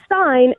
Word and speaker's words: Stein: So I made up a little rhyme, and it was, if the I and the E go a-walking, the second one does the talking. So if it's Stein, S-T-E-I-N, it's pronounced like an I, Stein.